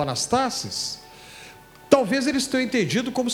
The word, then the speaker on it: anastasis: A palavra ressurreição em grego é anastasis, anastasis ou anastasis, dependendo da pronúncia, que faz lembrar Anastácia como um nome.